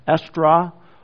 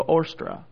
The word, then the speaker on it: Eostra: And it's spelled E-O-S-T-R-A. And depending upon your origin, as you pronounce the two letters, E-O-S-T-R-A, you might pronounce that Estra, or the O and the E and the O combined, and sometimes just give us the long O, Ostra.